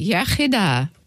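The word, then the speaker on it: iechyd da: You say yachida, good health.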